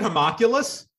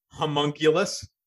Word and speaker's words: homunculus: Do you pronounce it homoculus? How do you pronounce it? homunculus.